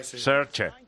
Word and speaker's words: Saoirse: de cómo pronunciamos a Susan Saoirse Ronan, porque los irlandeses dicen Saoirse, Saoirse Ronan. – Claro. – Entonces, y es Saoirse Ronan.